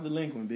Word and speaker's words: Dlequint: say is pronounced delinquent. – It's pronounced delinquent, bitch.